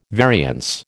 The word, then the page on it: variance: American English